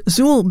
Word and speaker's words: Zuil: Recorded for the Telarc label in 2007 in a disc called Russian Masterpieces for Cello and Orchestra, we heard cellist Zuil Bailey. His first name is spelled Z-U-I-L-L.